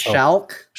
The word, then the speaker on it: Schalck: One from Ben Schlack, who wrote, Shalik. Shalik. Does that look right? I think I'm saying that right. – Shalk. – Shalk. – Shalk. – Shalk. – Shalk. – It's like S-C-H-A-L-C-K. Yeah